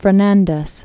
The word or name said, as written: Juan Fernandez Islands